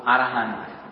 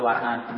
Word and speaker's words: arahant: Saddha, it gradually increases little by little up to arahant. Up to arahant means, you know, the enlightenment.